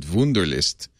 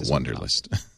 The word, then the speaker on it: Wunderlist: I have to think you're probably supposed to pronounce it Wunderlist. But I'm going to call it Wunderlist.